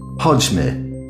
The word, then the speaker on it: Chodźmy: Chodźmy. Szybko. Ooh, that's awfully difficult to pronounce. Try it. – Oczywiście. Chodźmy.